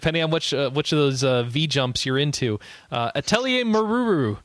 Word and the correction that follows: Meruru: Is that really how you... Meruru? How would you say it, Quinn? – Meruru.